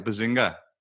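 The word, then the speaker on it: Behzinga: Bazinga? – Yeah, you got it.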